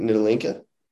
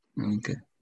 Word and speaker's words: Ntilikina: Frank Ntilikina. – Yeah. Ntilikina.